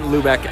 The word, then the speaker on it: Lubeck: Ben, B-E-N, Lubeck, L-U-B-E-C-K, .com.